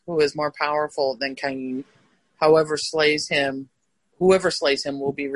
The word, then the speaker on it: Cain: If you say Lamech argued that I shall be punished by heaven, then I reply that if in the case of Cain, am I saying that wrong? Who was the first murderer? – In Hebrew, it's Cain.